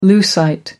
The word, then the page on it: lucite: American English